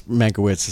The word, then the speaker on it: Mankiewicz: But I think it's Mankiewicz is how it's pronounced.